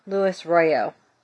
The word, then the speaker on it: Luis Royo: This is the Dead Moon I Ching, and it is by Luis Rojo. I believe is how you pronounce his name. Beautiful. Beautiful deck. I love his work. Royo. Luis Royo.